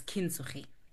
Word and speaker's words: Kintsugi: So Utandizindogwe therapist is through the art of Kintsugi or Kintsugi. Yeah, depending again with the pronunciation, but it's Kintsugi.